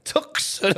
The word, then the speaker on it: Tucson: Is that how you pronounce it? – Tucson, Arizona. Tucson.